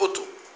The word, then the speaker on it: أطم: الأطم بضمتين، ضم في ضم على الألف وضم على الطاء، أطم تنطق أطم، هي بناء مرتفع كالحصن، عامل زي القلعة كده أو الحصن، وجمعه آطام.